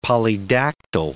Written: polydactyl

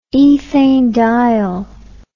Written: ethanediol

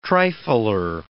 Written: trifler

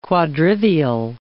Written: quadrivial

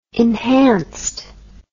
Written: enhanced

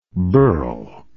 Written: berle

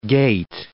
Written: gait

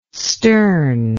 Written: Sterne

